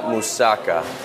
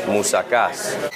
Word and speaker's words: moussakas: It's not moussaka, it's moussakas.